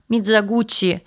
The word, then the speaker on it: Мидзогути: Я произношу фамилию режиссера Мидзагути. Хотя, если вы посмотрите, в поливановской транскрипции на русском языке написано везде Мидзагути. Дело в том, что в японском языке нет слога «ти», есть вот такой вот «ти».